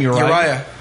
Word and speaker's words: Uriah: Uriah.